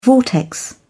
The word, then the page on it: vortex: British English